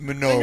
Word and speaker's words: Minogue: Yeah, it's pronounced Minogue.